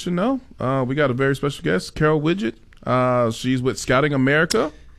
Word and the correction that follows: Wigget: I mean, it's not Widget, it's Wigget.